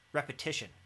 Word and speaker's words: repetition: it's structured on repetism. Did I say that right? Repetition. Repetition.